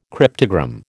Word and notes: cryptogram: American English

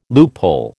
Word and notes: loophole: American English

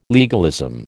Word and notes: legalism: American English

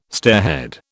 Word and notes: stairhead: British English

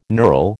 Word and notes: knurl: American English

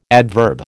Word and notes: adverb: American English

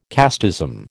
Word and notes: casteism: American English

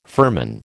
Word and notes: firman: American English

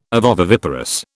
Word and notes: ovoviviparous: British English

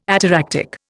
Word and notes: ataractic: Indian

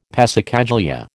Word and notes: passacaglia: American English

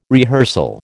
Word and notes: rehearsal: American English

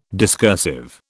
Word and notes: discursive: British English

